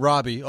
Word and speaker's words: Robby: There are people, like, they look at my name, Robbie, R-O-B-B-Y, and they'll literally be on Twitter typing something to me, and they will spell it R-O-B-B-I-E.